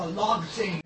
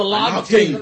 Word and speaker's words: lagting: So, it's pronounced a lagting. – Oh, the lagting. – Lagting.